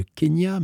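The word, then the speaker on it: Quenya: Once again, le Kenya, mais ça ne s'écrit pas comme le pays, ça s'écrit Q-U au début.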